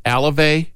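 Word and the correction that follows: Alzolay: Alzale. – Alzale.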